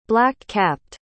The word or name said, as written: black-capped